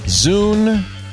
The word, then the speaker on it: Zune: Zune rhymes with doom.